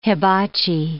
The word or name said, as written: hibachi